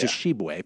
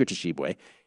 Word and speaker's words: Tshiebwe: And of course, right now I'm blanking, but Oscar Tshiebwe. – Big Oscar. – Oscar oh, yeah. – Tshiebwe. Pardon me. I wanted to get the pronunciation correct. Oscar Tshiebwe.